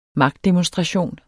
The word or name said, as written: magtdemonstration